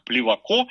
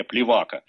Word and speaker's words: Плевако: Стал вскоре помощником присяжного поверенного, потом сам стал присяжным поверенным, работал с таким знаменитым адвокатом, как Плевако. Такое ударение, кстати, дает Википедия. Я сам и все мои знакомые всегда делали ударение Плевака.